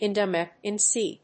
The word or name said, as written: Indomethacin